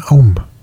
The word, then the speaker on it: Aum: Ganesha wird auch mit dem Aum, dem göttlichen Klang, identifiziert, hierzulande oft als Om ausgesprochen.